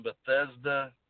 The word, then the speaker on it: Bethesda: Bethesda.